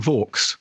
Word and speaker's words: Vaux: Well, the real life Anne Vaux, actually, we think she was called Anne Vawkes, rhyming with Fawkes. But of course, the reason we couldn't call her Anne Vawkes is because people would mix up Vawkes and Fawkes on screen. So we think the way that the family would pronounce it now would actually be Vawkes.